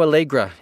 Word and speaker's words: Aalegra: Snoh, Aalegra is the singer, and her first name is spelled S-N-O-H, Snoh. And Aalegra has two A's at the beginning, A-A-L-E-G-R-A, Snoh, Aalegra.